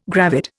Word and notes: gravid: Indian

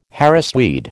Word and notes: Harris tweed: American English

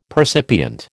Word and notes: percipient: American English

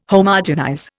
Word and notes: Homogenize: Indian

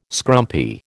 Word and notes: scrumpy: American English

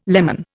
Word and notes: lemon: Indian

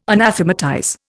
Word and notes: anathematize: Indian

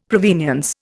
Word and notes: provenience: Indian

